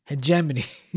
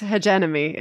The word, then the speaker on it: hegemony: It's like having to remind yourself how to pronounce the word hegemony. I did it. I think I did it. – Hegemony. – Hegemony. – Hegemony, but you think it's hegenemy, is it?